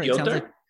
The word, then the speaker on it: Piotr: P-I-O-T-R? – Piotr? – How is it pronounced? – I don't know. It's pronounced like Piotr.